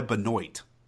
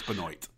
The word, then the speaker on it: Benoit: It's he goes by Benoit. – Really? – It makes no sense. It makes no sense, but it's Benoit.